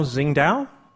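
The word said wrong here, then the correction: Tsingtao: Yes, I think you got the pronunciation a little wrong. – But it's close. We'll say Qingdao.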